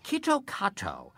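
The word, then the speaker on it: Kitokato (キットカット): Kitokato, the Japanese pronunciation of KitKat, sounds an awful lot like kitokatsu, which in Japanese means you surely will win.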